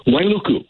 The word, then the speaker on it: Wailuku: Let's go to Wailuku. Wailuku, Maui. Am I saying that right? Wailuku? – Wailuku. – Wailuku. Hello, Wailuku. – Correct.